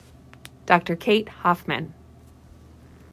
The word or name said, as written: Kate Hoffmann